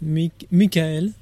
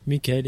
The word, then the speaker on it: Mikhail: My name is Mikhail. – Okay. – So Michael, but Mikhail in French.